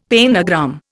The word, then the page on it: pentagram: Indian